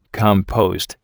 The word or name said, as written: compost